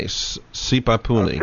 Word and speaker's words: Sipapuni: He says, I would appreciate if you can ask your guest about, I'll try to pronounce this correctly, Sipapuni. S-I-P-A-P-U-N-I. Sipapuni.